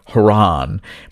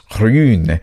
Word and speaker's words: Hraun: And I saw this candy bar called H-R-A-U-N, which, again, to me looked like Haran, but it's pronounced Hrun, and that means lava.